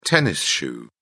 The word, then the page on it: tennis shoe: British English